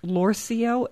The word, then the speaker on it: Lorcio: It looks like it's spelled or it's pronounced Lorcio, L-O-R.